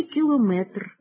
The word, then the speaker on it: кілометр: У слові «кілометр» навпаки слід наголошувати останній склад, як і в інших словах на означення довжини, складовою частиною яких є слово «метр», «міліметр», «сантиметр», «дециметр».